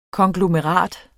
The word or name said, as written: KONGLOMERATER